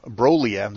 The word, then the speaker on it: Broglia: So this month, since we no longer have the Robert Broglia video, Broglia, there's no G in there. The G is silent, if you can believe it.